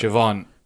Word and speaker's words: Siobhan: So the first one is spelled A-O-I-F-E. How would you pronounce that? – Aoife. – Aoife's correct. – Oh, wowee. – Next one is S-I-O-B-H-A-N. How would I pronounce that? – Siobhan. – Siobhan is correct.